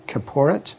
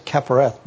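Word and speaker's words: kapporet: It's called the, I think, if I remember right, I think Dr. Boyd pronounced it Kiporet. So I always said Kiporeth, but he knows Hebrew. Kiporet.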